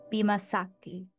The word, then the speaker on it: Bima Sakti: Scoot is a Catholic writer on Substack, boasting several newsletters, namely the Peasant Times Dispatch, Gibberish, Stained Glass Catechism, and Bima Sakti.